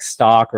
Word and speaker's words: Stok: So I think it's called like stock, or it's spelled STOK.